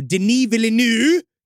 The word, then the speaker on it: Denis Villeneuve: So I knew that Dune 2, depending on what the H he was going to do, Denis Villeneuve, because now I know how to say his name, I want credit for that.